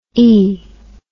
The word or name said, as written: ee